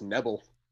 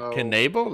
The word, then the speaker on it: Knebel: I thought it was just Nebel. So Knebel, that's how you say it. It's three syllables.